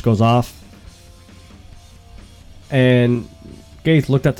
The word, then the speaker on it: Gaethje: But Gaethje, that's how you're supposed to pronounce it.